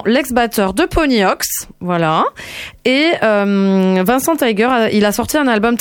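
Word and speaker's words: Vincent Taeger: Je dirais que ça se prononce Vincent Tiger, mais écrit T-A-E-G-E-R. Tiger.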